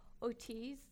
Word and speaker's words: Ortiz: Ortiz.